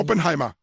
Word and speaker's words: Oppenheimer: ¿Cómo se pronuncia? – Oppenheimer.